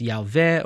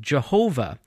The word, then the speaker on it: Yahweh: This is sometimes written out as Yahweh or Yahweh. This is where we get the mispronounced name Jehovah.